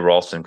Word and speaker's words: Ralston: And you keep hearing me say Ralston, because that is actually how you say it.